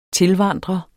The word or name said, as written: tilvandrer